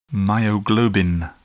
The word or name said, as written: myoglobin